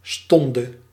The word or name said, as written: hour